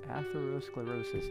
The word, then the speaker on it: atherosclerosis: Atherosclerosis, that's how it's pronounced, boys and girls.